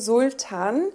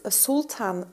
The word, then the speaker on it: Sultan: Ähm, mein Name ist ja Sultan. Also im Deutschen wird es äh, ziemlich hart ausgesprochen, Sultan. Aber ja. eigentlich äh, spricht man es Sultan aus.